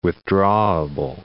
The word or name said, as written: withdrawable